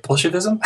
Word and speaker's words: Bolshevism: what a marvelous piece of work that was totally shoring up Bolshevikism and that's not how you say it, Bolshevism.